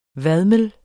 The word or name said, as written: vadmel